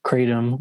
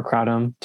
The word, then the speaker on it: kratom: Graham alluded earlier to the deuterated status of the molecule, but mitragynine is one of two active molecules from kratom or kratom. Some people pronounce it differently.